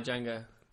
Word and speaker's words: Django: Pardon my Django. – Pardon my Django. Bottom of my jangling of my chains. – Oh, the D is silent.